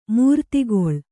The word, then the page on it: ಮೂರ್ತಿಗೊಳ್: mūrtigoḷ